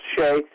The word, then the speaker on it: Hsieh: And Tony Hsieh, uh, his last name, by the way, is spelled H S I E H. It is pronounced Shea.